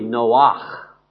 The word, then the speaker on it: Noach: And we're talking about Lamech and Noah, that's a guttural sound. It's really Noach. And so it's spelled with a CH.